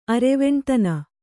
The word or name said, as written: ಅರೆವೆಣ್ತನ